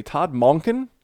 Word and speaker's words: Monken: And then they hired, I hope I'm pronouncing this correctly, Todd Monken. – I think that's right. – That sounds right.